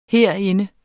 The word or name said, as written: herinde